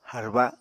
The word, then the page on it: harba: apatani